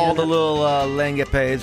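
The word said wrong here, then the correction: lagniappe: Lanyap. – I always say langapes. See, Lanya. – All the little extras. – A little extra.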